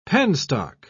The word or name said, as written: penstock